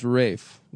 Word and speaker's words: Ralph: There wasn't an L. But it is pronounced Rafe.